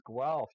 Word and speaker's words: Guelph: You're coming all the way from Ontario, Canada, out of Guelph, is that what it's called? – It's Guelph. You pronounced it correctly. Yes. The spelling looks like you might have to sound like a cat with a hairball to pronounce it.